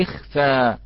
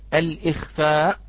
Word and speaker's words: الإخفاء: ولفظ الإخفاء أخوتي الكرام، ولفظ الإخفاء عند الابتداء بها تلفظ باللام، نقول لخفى، نقول لخفى، لا نقول الإخفاء، نقفى، وهو بالنقل مع الابتداء باللام التي حركت بحركة الهمزة وهي الكسرة على قراءة ورش وحمزة وقفا.